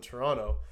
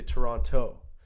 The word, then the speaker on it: Toronto: And they said, you can always tell who's not from Toronto because they say Toronto. – Toronto. – They say they drop a syllable. They drop the T.